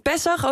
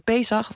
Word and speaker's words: Pesach: Ik vond Pessach ook bijzonder uitgesproken. In mijn contraire gaat het meestal over Pesach of over Pasen, of eigenlijk überhaupt niet over het geloof. Maar blijkbaar kun je het ook zo zeggen.